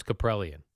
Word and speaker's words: Caprielian: James Caprielian. – I believe that's how you pronounce his name.